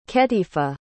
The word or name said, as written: ketifa